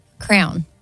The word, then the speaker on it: crayon: Crayon. – Excuse me? – A crown. – I'll accept it. You said crown, but it's crayon.